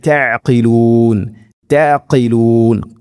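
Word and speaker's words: തഅഖിലൂൻ തഅഖിലൂൻ (تَعْقِلُونَ): ഇൻദ ഇൻദ റബ്ബിക്കും അഫല തഅഖിലൂൻ തഅഖിലൂൻ, ഖാഫിന്റെ ഉച്ചാരണം പ്രത്യേകം ശ്രദ്ധിക്കുക.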